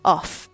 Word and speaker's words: of: OK，这边的 of 跟刚才那个 off off 不太一样，这个是 of 这个。 F 的发音虽然在这边是 F，但是呢，它的发音是 V 这个声音。